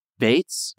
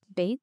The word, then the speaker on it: Beetz: I think it's just Bates. – Bates.